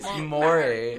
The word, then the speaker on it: Maury: How do you think you pronounce Maury Povich? – Maori. – I think it's Maori. – Maori. – Not Maori. – It's Maury.